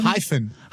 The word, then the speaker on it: Hyphen: Wie wird das aussprechen? Hyphen? – Hyphen.